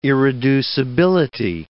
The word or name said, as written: irreducibility